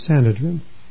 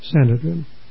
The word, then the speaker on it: Sanhedrin: So Christ was brought before the pronunciation that we give, that I give, is Sanhedrin. Accent of the first syllable. That's the way I've been pronouncing the word. Sanhedrin.